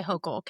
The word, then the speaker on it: Hochul: How do you pronounce her last name? – I always say Hochul, Kathy Hochul.